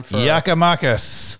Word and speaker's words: Giakoumakis: Giakoumakis.